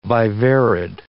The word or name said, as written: viverrid